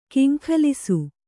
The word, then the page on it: ಕೆಂಗಲಿಸು: keŋgalisu